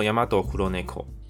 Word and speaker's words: ヤマトクロネコ: 那黑猫商标名称念作 Yamato Kuroneko，所以全新的黑猫商标将从下个月四月一号开始使用。